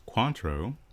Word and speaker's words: Cointreau: That's how you say it? – Yeah. – I say Cointreau. No, not kidding. I say Cointreau. – No. – Or Cointreau. – Yeah, like, gotcha. It's like French pronunciation.